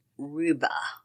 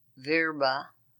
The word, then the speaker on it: Vrba: Francis Allen Ruba, it's spelled V-R-B-A, of Marengo. Francis Allen Ruba, known as Frank, 81, of Marengo, Iowa, passed away May 6, 2023, at Compass Memorial Healthcare in Marengo.